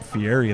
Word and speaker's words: Fieri: Wow, you really churched up Fieri there. – Yeah, you hate it when I do that, but that's how you pronounce it.